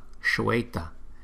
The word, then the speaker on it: Shweta: Maha Shweta, Maha Shweta. Lưu ý chữ S dấu sắc trên đầu chúng ta đọc là Sh, Shweta, Shweta, Maha Shweta.